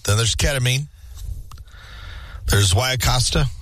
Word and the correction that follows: ayahuasca: Or, how do you say that? – Ayahuasca. – Ayahuasca, yeah. – I believe that's how it's said.